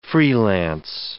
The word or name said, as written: freelance